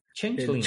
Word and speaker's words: Changeling: Bueno, a ver, entonces esta novela de Changeling, como, ¿cómo, ¿cómo lo pronunciaste? – Changeling. – Changeling, ajá. Buena, buena pronunciación, sí. – digo, pronunciación bostoniana, sí.